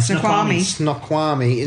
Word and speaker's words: Snoqualmie: Snoqualmie.